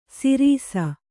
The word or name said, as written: ಸಿರಿಸ